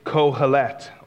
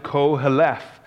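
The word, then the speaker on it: Kohelet (קהלת): And the preacher there, the word translated preacher is Kohelet, or you could pronounce it Koheleth.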